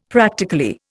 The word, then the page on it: practically: Indian